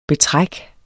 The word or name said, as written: betræk